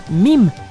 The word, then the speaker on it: meme: Llamó meme a esta unidad, que en inglés se pronuncia meme.